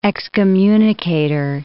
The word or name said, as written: excommunicator